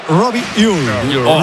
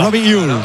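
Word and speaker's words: Robbie Ure: En concreto sería Robi Ure. Robi Ure.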